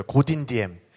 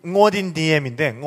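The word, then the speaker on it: Ngô Đình Diệm: 그래서 이 사람도 그 응우엔 왕조의 후손인데 그 원래 현지 발음은 응어딘 디엠인데 응어딘 디엠. 그래서 응어라는 발음이 없으니까 그냥 고라고 쓴 거예요. 그러니까 그런 식으로 그 베트남 이름 중에 좀 표기가 좀 애매한 것들이 맞아요.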